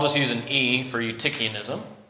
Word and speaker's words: Eutychianism: That starts with an E. That's pronounced Eutychianism, okay?